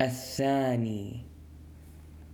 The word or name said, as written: الثاني